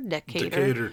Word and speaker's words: Decatur: I think that's how you say it? Decatur? Decatur, typically. Georgia.